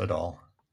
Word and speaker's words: Adol: Adol could now come back and be the guy to save the universe again in the land of East. – I'm sorry. It's pronounced Adol. – Yeah, I know. Adol.